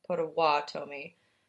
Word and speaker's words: Potawatomi: I heard a lot of different pronunciations, and I did want to get it correct. They were later overpowered by white settlers. Potawatomi. – I literally watched, like, a YouTube video. It's like Potawatomi. Awesome people.